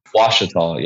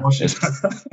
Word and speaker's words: Ouachita: Uh, Washita, yeah. – Washita. – like a silent W, but yeah, Washita.